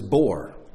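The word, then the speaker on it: bor (בור): Pit is bor.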